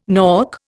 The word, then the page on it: nork: Indian